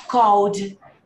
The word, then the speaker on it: called: Ok, cuidado com college, hein? College que você falou, se você pronunciar desse jeito, você tá falando faculdade. Esse daqui, ele tem a pronúncia assim, ó. Assim, ó. Called. – Called.